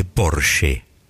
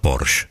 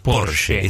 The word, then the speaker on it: Porsche: La marca es alemana y se dice Porsche. – no Porsche. – Porsche. – Esa E final en alemán se pronuncia.